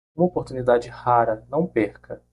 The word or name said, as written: perca